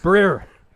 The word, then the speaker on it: Brir: Breer. – Breer? – Breer. – Can you spell that? – B-R-I-R.